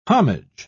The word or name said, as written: homage